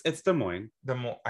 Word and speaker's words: Des Moines: Like, okay, but who else did I say it wrong? Des Moines. – No, it's Des Moines. – I said Des Moines. – Yeah, sorry. You don't say the S's. – I know, sorry, it's French.